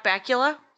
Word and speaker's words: Bakula: Scott Bakula's name rhymes with butt crackula.